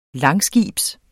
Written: langskibs

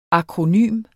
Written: akronym

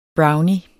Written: brownie

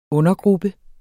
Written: undergruppe

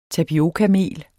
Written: tapiokamel

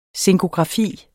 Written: zinkografi

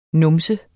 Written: numse

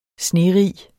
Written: snerig

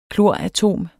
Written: kloratom

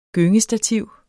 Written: gyngestativ

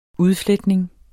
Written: udfletning

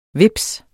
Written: vips

